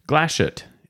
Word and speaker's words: Glashütte: Made in Glashütte. Glashütte is how you pronounce that.